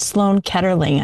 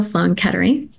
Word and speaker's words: Sloan Kettering: You work in the yoga and cancer care of what, Sloan Kettering. I can never say that word. Did I say it right? – Yes, Memorial Sloan Kettering Cancer Center.